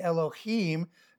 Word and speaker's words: Elohim: So the word Eloah means God, and Elohim, the I am, pronounced E, long E, Elohim, is a plural for God.